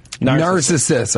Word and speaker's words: Narcissus: Narcissus, how do you pronounce it? – Narcissus. – Our producer is like, it's Narcissus.